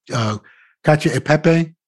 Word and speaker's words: Cacio e Pepe: And he made it, I always mispronounce it, it's easier, Cacio e Pepe? – Yeah, that's not mispronounced.